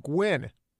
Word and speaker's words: Nguyen: Engine?" – "It's pronounced Gwyn," Daniel said. N-G-U-Y-E-N.